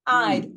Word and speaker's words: I'd: I'd. Ojo con D al final.